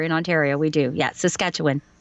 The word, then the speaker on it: Saskatchewan: I actually did a job for someone in Saskatchewan, and I was trained to say Saskatchewan, and they corrected me. They said, why do you keep saying that? And I said, oh, well, I'm Canadian. That's how you say it. They go, no, it's not.